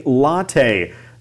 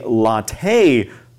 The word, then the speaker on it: latte: We're calling it a latte. That is the pronunciation we use in the US. We don't say latte like they might in Italy or some other places.